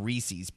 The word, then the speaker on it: Reese's: Nate calls them Reese's Pieces. Yeah. Yeah. – Reese's. It's Reese's Pieces. – It doesn't rhyme with feces.